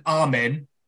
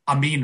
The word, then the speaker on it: Amin: As it's, you know, it's spelled A-M-I-N, but it's pronounced as if it's A-M-E-E-N. So in England, I'm known as Nathan Armin because of the way just the English people pronounce a word with I in it. Whereas in Wales, because we do pronounce words with an I, with a double E, it actually works. So like, you know, the Welsh and the Arabic get Amin right.